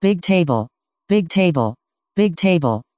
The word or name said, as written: bigtable